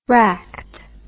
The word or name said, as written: racked